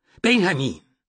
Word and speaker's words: Benjamin: He didn't say anything, but received a sharp look from his mother and a full Spanish pronunciation of his name. – Benjamin!